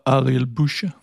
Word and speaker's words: Ariel Busche: Og så mener jeg, den sidste, han hedder Ariel Busche. – Ja. – Er det ikke cirka sådan, det udtales? – Jo.